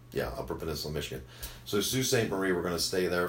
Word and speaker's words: Sault Ste. Marie: From there we go to, and this is another one when you look at the name, I am going to pronounce this correctly, but it's going to look wrong. So it's Sault Ste. Marie.